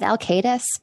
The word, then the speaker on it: Alcaides: I go with Alcaides, but I don't know that I'd ever heard anybody pronounce it before I put it in the novel. So I feel like anything works.